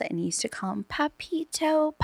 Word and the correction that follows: Pepito: Papito. And I was like, Dad, you're calling him like Papito, like he's your gay buddy. – Like daddy? – Yeah. And he goes, but how else do you say it? And I go, you know, Pepito.